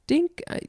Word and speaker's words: Dinka: And Dinka, that's how you pronounce it.